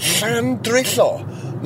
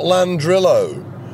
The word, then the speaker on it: Llandrillo: First of all, the astonishing thing was that it used the Welsh name, and second of all, it pronounced it in the Welsh way, Llandrillo, not Landrillo.